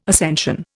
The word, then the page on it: ascension: Indian